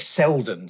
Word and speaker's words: Selden: It's Psychology of the Stock Market, written by a guy called Steddon. If my memory, I always get his surname wrong. Yes, Selden, sorry, S-E-L-D-E-N, Psychology of the Stock Market, 1912.